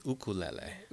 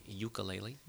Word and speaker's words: ukulele: The Hawaiian pronunciation is ukulele. – Ukulele. – Ukulele. Here in the mainland, we say ukulele.